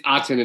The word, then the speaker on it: Aten: Did I say it wrong? – It's Aten and it's Akhenaten. – It just says A-T-E-N.